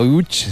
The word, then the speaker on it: Łódź: Aunque se pronuncia Łódź, se escribe Lodz, por si alguien lo busca.